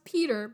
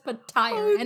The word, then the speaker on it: Petyr: It's supposed to be pronounced Peter, but he says Petyr, and it drives me insane.